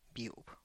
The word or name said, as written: biub